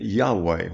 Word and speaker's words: Yahweh: So in German, it's pronounced like a Y. So we get Yahweh when it's translated correctly.